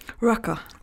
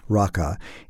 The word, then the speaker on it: Raqqa: And he was actually following Kurdish soldiers who are now, he reports, I believe he said about 20 miles or so from Raqqa. Is that how you pronounce it, by the way? – Raqqa. – Raqqa.